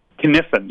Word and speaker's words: Cynefin: Is it Cynefin? – Cynefin, yeah. It's Cynefin. The Cynefin framework. Yeah, it's a Welsh word.